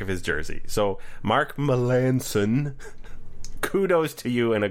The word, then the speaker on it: Melancon: So there is a relief pitcher who, if you were to look at his name, would look like Mark Melancon. Melancon. Would you not agree? – Yeah, that is how it looks like it's spelled. – Looks like that. That's not how you say it.